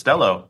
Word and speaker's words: Stelo: Is it pronounced Stelo like hello? – It is Stelo. You got it.